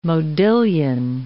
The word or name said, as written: modillion